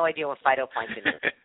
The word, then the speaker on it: phytoplankton: Valerie, did I say that correctly? Phytoplankton? Was that? – Yes. Phytoplankton, my favorite thing in the whole world.